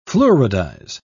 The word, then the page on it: fluoridize: British English